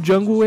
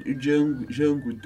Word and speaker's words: Django: Tipo, Django, o D é mudo.